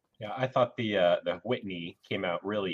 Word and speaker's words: Whitney: That's how you have to pronounce it. It's W-H, Whitney, like cool whip.